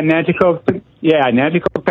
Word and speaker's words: Nanticoke: Am I saying that right? – Yeah, Nanticoke, Pennsylvania.